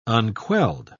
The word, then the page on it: unquelled: British English